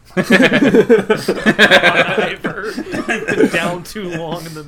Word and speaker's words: Bon Iver: It must have been Bon Iver or something like that.